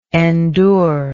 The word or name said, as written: endurer